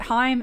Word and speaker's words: Haim: Because is it Haim? Is that how you say it? – Yeah.